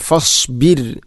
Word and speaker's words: فاصبر: റായിനെ കനപ്പിച്ചുകൊണ്ട് ഒരിക്കലും അങ്ങനെ പറയരുത്. ഫസ്ബിർ, റായിന് തർക്കീക്കാണ്, അഥവാ നേർപ്പിച്ചുകൊണ്ട് ഉച്ചരിക്കണം ഫസ്ബിർ.